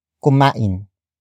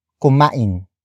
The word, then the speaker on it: kumain: After that, we have kumain. Kumain. Kumain. Kumain is spelled as K-U-M-A-I-N. It means ate, or the act of eating has happened.